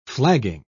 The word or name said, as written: flagging